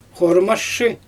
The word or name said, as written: xoramaʃʃɯ